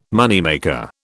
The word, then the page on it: moneymaker: British English